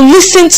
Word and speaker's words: listen: So the T is silent. Yes, and the pronunciation is listen.